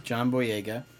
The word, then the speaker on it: John Boyega: John Boyega.